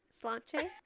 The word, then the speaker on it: Sláinte: Sláinte. Yes. – Slanche? – Sláinte.